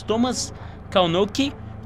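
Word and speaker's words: Kalnoky: Thomas Kalnoki,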